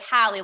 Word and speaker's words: Hallie: And I'm like, Hallie, like Halle Berry, because nobody forgets her name.